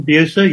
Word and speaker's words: Bucer: His name is Martin, I think it's Bucer. Is that how you say it? – Martin Bucer, yes.